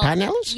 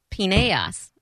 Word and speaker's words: Pinellas: Yes, and I didn't know how to pronounce it. And I thought, because there was a big Hispanic population, that it was Pineas. – Like Pineas. – I called it Pineas until I heard someone else call it Pinellas.